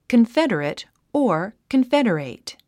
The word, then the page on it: confederate: kənfédərət; コンフェデレト